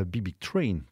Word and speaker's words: Big Big Train: Voici Big Big Train, à Big Big Train, pour le prononcer correctement, avec le morceau Winky.